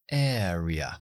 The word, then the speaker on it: area: O la palabra zona o área, que es area. Area. Air. Empezando así. Area. Muy importante. Air. Area o incluso padre, que estaba en la frase de ejemplo.